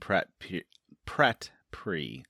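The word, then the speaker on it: Pyat Pree: Danny enters Karth to a great welcome and promises from Zaro, Zoan, Doxos, and Pratt Pree. Did I say that right? – You did.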